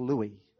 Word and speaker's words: Louis: Apparently yesterday, over a two-hour period, the most Googled question was, how do you pronounce the name Louis? Because of the royal birth. So there you go. You've learned something. It's Louis.